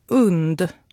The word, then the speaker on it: UND: Vi på radion har blivit informerade om att det ska uttalas UND. Tänk hund minus H.